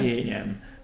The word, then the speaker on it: Vőlegényem: Vőlegényem. – Vőlegényem. – Mm-hmm. – You know, it's a vőlegényem. It's many. You have to stretch. – Stretch. Yes, that's it.